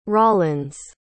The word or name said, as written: rollins